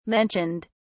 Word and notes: mentioned: British English

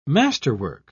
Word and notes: masterwork: British English

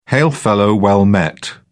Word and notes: hail-fellow-well-met: British English

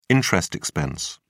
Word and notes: interest expense: British English

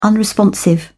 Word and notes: unresponsive: British English

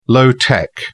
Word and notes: low-tech: British English

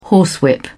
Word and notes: horsewhip: British English

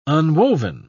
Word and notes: unwoven: British English

